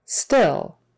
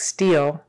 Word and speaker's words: still: Make sure it's relaxed so it doesn't sound like steel. We don't want steel. We want still, still. Because steal is a different word, right? It's when you take something from somebody without permission. Steal is long E. We don't want that for the word still, like still happening. Still short I.